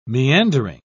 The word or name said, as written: meandering